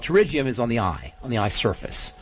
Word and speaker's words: pterygium: Please provide a treatment protocol for pterygium. Did I pronounce that right? P-T-E-R. – Yeah. – Why? – Well, G-I-U-M. – Let's talk about pterygium.